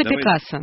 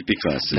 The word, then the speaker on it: Пикассо: Все-таки, что с ударением? – Нет, давайте. Раз у нас язык такой, который любит делать природные ударения, то есть самые первоначальные. – Так. – Мы всегда, в отличие от очень многих европейцев, мы всегда блюдем чистоту ударений и произношений, которые есть в том языке, из которого человек там произошел. То есть, так что Пикассо. – Давайте Пикассо. – Да, это аутентичный.